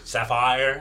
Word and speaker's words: Sapphire: Que es Sapphire. – Sapphire. – La pronunciación.